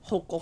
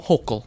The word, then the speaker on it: Hochul: Kathy Hochul. – Hochul, thank you.